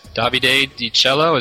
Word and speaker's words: Davide Di Cello: With us right now, we have our guest, Davide Di Cello. Is that how you pronounce it, Davide? – Yep, Davide Di Cello, correct.